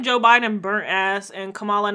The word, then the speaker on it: Kamala: I keep telling you, it's like the same syllable for cop. Kamala. Cop. Kamala.